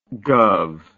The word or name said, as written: gov